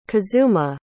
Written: kazuma

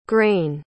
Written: grein

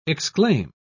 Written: exclaim

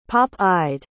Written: pop-eyed

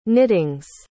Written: knittings

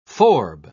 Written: forb

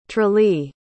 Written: tralee

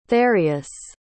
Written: therius

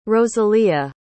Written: rosalia

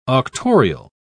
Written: auctorial

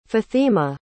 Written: fathima

